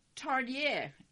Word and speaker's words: Tarnier: In the 1870s, Etienne Stepanet, you know I don't know how to pronounce these right, Tarnier, key word here, T-A-R-N-I-E-R, Tarnier, a physician, began to study the problem of premature infants and found that maintaining a constant temperature was not enough.